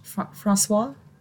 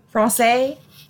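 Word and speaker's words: français: Francois? Is that how you say it? – Francais.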